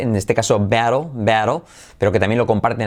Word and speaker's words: battle: Y se pronuncia como ea, battle.